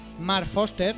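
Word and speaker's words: Marc Forster: Vamos a empezar por Guerra Mundial Z, película dirigida por Mark Foster.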